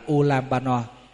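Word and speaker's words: Ullambana: Chữ Vu Lan Bồn này tiếng Phạn đọc là Ulambano.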